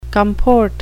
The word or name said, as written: comport